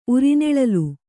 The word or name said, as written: ಉರಿನೆಳಲು